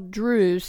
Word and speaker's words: Druse: And why don't you spell Drews so everybody knows it sounds like Cruise, but how do you actually spell it? – D-R-U-S-E.